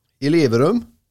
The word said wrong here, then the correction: Elverum: Elverum. – Ja, det är klart att det var fel uttal där, Bojan.